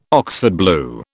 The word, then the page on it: Oxford blue: British English